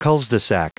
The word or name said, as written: culs-de-sac